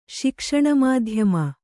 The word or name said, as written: ಶಿಕ್ಷಣಮಾಧ್ಯಮ